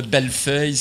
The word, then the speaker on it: Bellefeuille: En fait, parce que je, tu, sais, sais, j'ai commencé à, faire de l'anglais là, euh, pour, le plaisir, puis les Anglais sont, incapables de prononcer mon nom. Le Bellefeuille, c'est impossible, mais Bellefille, belle fille, peut-être.